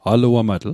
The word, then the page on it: aluamatl: Mi'gmaq